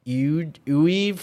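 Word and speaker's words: Iwuji: It's weird to say his last name. It's so it's Uwe for for the last name. Like I said, it's very odd name to pronounce. Chakwu Uwe.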